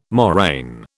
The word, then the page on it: moraine: British English